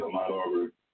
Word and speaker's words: Ahmaud Arbery: I want you to tell us what's happening with this, and correct me if I'm mispronouncing the brother's name, Ahmaud Arbery? – That's it, Ahmaud Arbery.